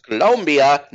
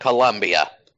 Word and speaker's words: Colombia: By the way, also to remind people, it's pronounced Colombia, not Colombia. Colombians get very upset if you do that.